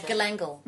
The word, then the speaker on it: galangal: Well, our root our, our root vegetables like ginseng and garlic and gin, um, ginger and turmeric, turmeric and and gangling, yes. gang, what? Yeah, how do you say that one? that one? You know the one I'm talking about. Uh, galangal. Galangal. Thank you.